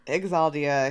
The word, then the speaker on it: Xhaldia: It's spelled X H A L D I A, like Exaldia, Shaldia. – Exaldia, I think is we lean on to the X.